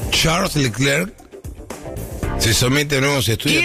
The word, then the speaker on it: Charles Leclerc: Charles Leclerc.